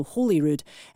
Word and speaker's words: Holyrood: Holyrood as in holy, not Holyrood as in holly tree, so Holyrood.